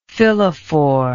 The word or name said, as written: phyllophore